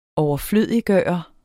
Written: overflødiggøre

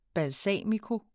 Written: balsamico

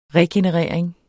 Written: regenerering

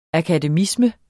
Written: akademisme

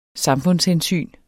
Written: samfundshensyn

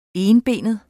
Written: enbenet

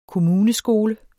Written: KOMMUNESKOLER

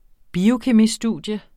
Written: biokemistudium

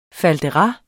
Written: faldera

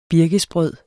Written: birkesbrød

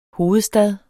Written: hovedstad

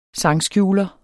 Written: sangskjuler

